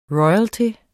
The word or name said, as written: royalty